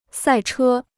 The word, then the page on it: 赛车: sài chē